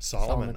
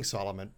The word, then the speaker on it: Salamun: Salamun, that's right. – Because it's spelled differently. – It's spelled differently, S-A-L-A-M-U-N, but it sounds like Salamun, so like King Salamun, but not quite as wise.